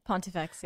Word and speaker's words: Pontifex: Now, how you say it's Pontifex? – Pontifex, yeah.